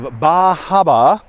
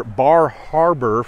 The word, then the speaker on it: Bar Harbor: Anyway, this is in Maine, just outside of Bahaba, or Bar Harbor, for those of you that can pronounce R's.